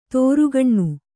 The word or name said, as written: ತೋರುಗಣ್ಣು